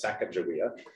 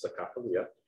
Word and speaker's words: Sacagawea: The little robot is, in this case, was dressed up like Sacagawea, which here in Minnesota, everybody says Sacagawea, but right, the proper local pronunciation is Sacagawea.